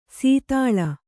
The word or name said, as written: ಸೀತಳ